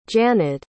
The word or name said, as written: janyd